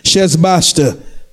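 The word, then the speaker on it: Sheshbazzar: Shesbaster." I know it's easy, but that's how it's pronounced. Shesbaster, whom he made governor.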